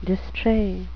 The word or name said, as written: distrait